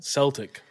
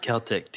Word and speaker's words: Celtic: At that time, Celtic Football Club, who are pronounced Celtic Football Club, not Celtic, too. – Were known to make frequent tours. Not Celtic.